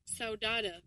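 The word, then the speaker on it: saudade: Sodada. – Oh, Sodada. – Sodada. Sodada. I want to be that all the time because it sounds cool. How you doing? I'm Sodada.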